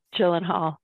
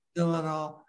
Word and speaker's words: Gyllenhaal: Gyllenhaal. – Gyllenhaal.